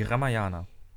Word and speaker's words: Ramayana: Die Ramayana.